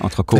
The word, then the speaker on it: entrecôte: Men, men det er et ord, som, som rigtig mange udtaler entrecote fordi de har lært, at på, i fransk ord skal man ikke udtale et slut-t, men det skal man faktisk her, hvis ja. man skal sige det på fransk.